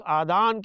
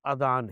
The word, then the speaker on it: اذان: اذان کی تلفظ کے سلسلے میں بھی بعض لوگ غلطیاں کر دیتے، کوئی آدان کہتا ہے، کوئی اذان کہتا ہے، تو سن لیجئے۔ آدان جو ہے نا، یہ اذن کی جمع ہے اور ان کے معنی ہیں کان، لہذا آدان نہیں کہیں گے، صحیح تلفظ اذان ہے،